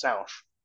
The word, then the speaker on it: Sanche: It actually spells out Sanche. So it's S-A-N-C-H-E, but it's French, so it's pronounced Sanche. – Sanche. – Sanche, yeah, exactly.